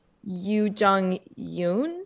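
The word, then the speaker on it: Youn Yuh-jung: I think, and please correct my pronunciation, Yoo Jung Yoon. – Yes. – Yoon. – Well done.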